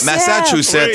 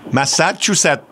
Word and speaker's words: Massachusetts: Juste massa. – Massa comme d'un massacre, là. – Massachusetts. – Ouais, il est pas facile, ce bout-là, non plus. – Massachusetts.